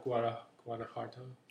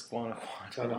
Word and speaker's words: Guanajuato: Of Guanajuato. – It's Guanajuato. I'm sorry. Mispronounce it the way I mispronounce it, damn it.